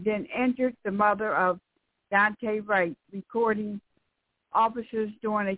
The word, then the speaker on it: Daunte: Now, the first one is, police union praises cop who injured Dante Wright's mother. I hope I said his name right. D-A-U-N-T-E. Dante, okay.